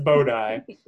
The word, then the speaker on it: Bodhi: Yeah, it's an orb named Bodai, which is spelled like Bodhi, but pronounced Bodai.